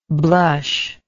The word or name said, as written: blushless